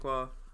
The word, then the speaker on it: Dankwah: I'm David Dankwah.